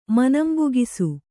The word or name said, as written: ಮನಂಬುಗಿಸು